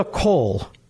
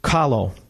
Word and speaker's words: Kahlo: Further on his page, I love the innocence of this image of a young Frida Kahlo. That you know who I'm talking about. I'm butchering her last name. Kahlo.